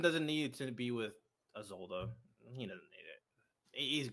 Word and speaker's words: Isolde: Like, is that how you pronounce it, Isolde? – Mm-hmm.